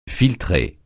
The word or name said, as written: filtrer